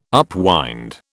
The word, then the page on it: upwind: British English